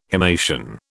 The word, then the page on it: himation: British English